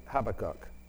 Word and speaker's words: Habakkuk: Habakkuk, as Americans say it. Brits say Habakkuk. Can you all say Habakkuk?